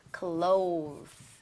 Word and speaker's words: clothes: 아쉽게도 clothes죠. 방금 한 거 사실 하면은 cloth 발음도 다른데요. 그건 천을 얘기를 하는 거예요.